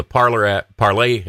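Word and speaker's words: Parler: Just got on the Parlay. I keep saying Parlor because that's the way it's spelled.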